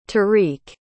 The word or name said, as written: tarik